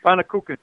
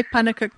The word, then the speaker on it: pannekoek: It's just pronounced pannekoek. – Pannekoek. – You actually did it very well.